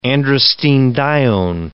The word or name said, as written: androstenedione